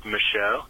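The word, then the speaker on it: Michaux: I know one of you guys has family up in Pennsylvania, and I wanted to say that the state park up there is pronounced Michaud.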